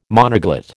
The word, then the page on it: monoglot: American English